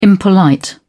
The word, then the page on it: impolite: British English